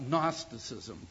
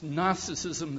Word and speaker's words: Gnosticism: Any time you talk about a word that is pronounced Gnosticism that starts with a G, you know that there's something the matter with that, you know? You should never have words start with G and you pronounce them Gnosticism.